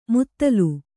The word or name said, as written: ಮುತ್ತಲು